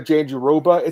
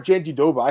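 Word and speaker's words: Jandiroba: It's not Janjidoba. I heard your pronunciation, or is it Drake Riggs or someone? Is that who it was? They like had yeah. a clip of her saying it. I don't know why Jon Anik says it's like Janjidoba because she herself said Janjiroba, and I'm like, well, that's what it, that's how it's spelled.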